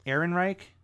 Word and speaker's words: Ehrenreich: Ehrenreich.